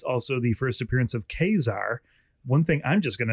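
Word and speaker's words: Ka-Zar: Did you know that Kazar was pronounced Kazar? I grew up pronouncing it Kazar. – Yeah, I knew it was Kazar.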